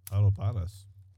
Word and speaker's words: Adobadas: How do you say that? – Adobadas. – Adobadas? – Yeah, that's what I would say.